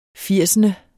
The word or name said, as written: firsende